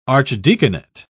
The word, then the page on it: archdeaconate: British English